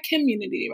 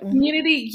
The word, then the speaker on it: Kemunity: Is that how you say it? Am I saying it right? Community, right? – Yep, that's correct. – Community Yoga. Spelled K-E-M-U-N-I-T-Y Yoga.